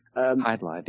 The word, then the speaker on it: Hydlide: I never know how to pronounce this. Hydlide, I guess it's called. – Hydlide, yeah.